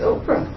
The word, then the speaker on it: Ophrah: It's Ophrah. – Mine got another H, so it'd be an F sound. Ophrah. Or Oprah.